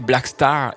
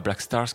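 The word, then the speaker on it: Blackstar: Grosse influence, si vous avez aimé ce morceau, allez écouter Black Star et pas Black Stars comme je vous le disais. Et oui, attention à la prononciation, c'était Black Star donc de David Bowie.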